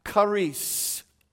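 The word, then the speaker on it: charis (χάρις): In the original, it's pronounced charis.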